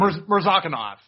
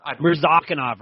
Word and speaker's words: Mirzakhanov: Jared Vander Aa versus Azamat Mirzakhanov. Did I say that right? I can't remember how to say his name. – Mirzakhanov. – Mirzakhanov, right.